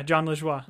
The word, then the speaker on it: Jean Lajoie: Is that how you say his name? – Yeah, Jean Lajoie.